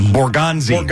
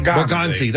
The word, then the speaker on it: Borgonzi: When the new general manager, how do you say his name down in Tennessee? Borgi or? – Borgonzi. – Borgonzi, that's right.